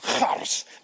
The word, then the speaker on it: Charis: Charis is the Greek word for grace. Actually, it's pronounced Charis, but she won't let me call her that anymore.